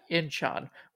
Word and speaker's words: Incheon: At Incheon, which is near Seoul. What? Did I say it wrong? – No, you said it right.